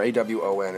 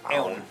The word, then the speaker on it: Aown: It's A O W N or A W O N. It's not one. It's like Awon. Aon. Aon. Yeah, something like that.